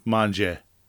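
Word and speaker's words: Mongeon: It's a silent N. Monahan.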